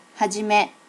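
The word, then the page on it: hajime: native Japanese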